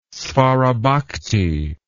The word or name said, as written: svarabhakti